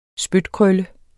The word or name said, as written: spytkrølle